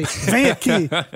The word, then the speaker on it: vem aqui: And the informal way, because you don't say the second syllable, the nha. You don't say the nha. – You don't say nha. – Yeah, so you just say vem aqui, vem cá, vem aqui or vem cá.